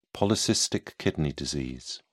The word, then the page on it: polycystic kidney disease: British English